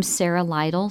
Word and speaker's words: Sarah Lyttle: You can check me under my name, Sarah Lytle, spelled with an H on the end of Sarah, and L-Y-T-T-L-E.